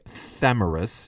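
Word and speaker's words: Tomyris: Then moving on to the next, third exemplar, the pavement showed the way in which Thomeris, or if you want to pronounce her name in another way, Thamyris, Thomeris made ruin and cruel slaughter when she said to Cyrus, "You were thirsty for blood.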